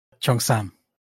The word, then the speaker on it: Cheongsam: Cheongsam.